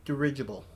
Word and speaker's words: dirigible: And let's go ahead and let's talk about the dirigible. Did I say it right? – Yes.